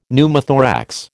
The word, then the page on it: Pneumothorax: American English